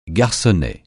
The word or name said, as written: garçonnet